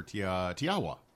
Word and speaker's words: Te Awa: We have the winemaker for Tiawa. – That's right. – Not Tiawa. Tiawa.